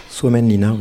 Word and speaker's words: Suomenlinna: Donc vous nous avez proposé un extrait justement pour parler de cadrage, celui en Finlande, sur l'île de Suomenlinna, si je le prononce bien. – Suomenlinna, oui, c'est en face d'Helsinki.